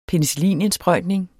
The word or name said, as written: penicillinindsprøjtning